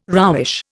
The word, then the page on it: roughish: Indian